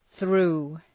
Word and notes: through: ɵru:

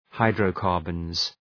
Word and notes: hydrocarbons: {,haıdrəʋ’kɑ:rbənz}

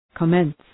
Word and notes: commence: kə’mens

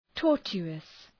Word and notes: tortuous: ‘tɔ:rtʃu:əs

